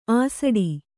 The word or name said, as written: ಅಸಡಿ